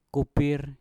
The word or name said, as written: kupir